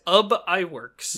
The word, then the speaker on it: Ub Iwerks: So Ub Iwerks, who I looked up YouTube videos how to pronounce it. I believe his name was in fact Ub Iwerks.